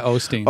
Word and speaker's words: Osteen: holier than thou Osteen. Is that how you pronounce his name? – Yeah, Osteen.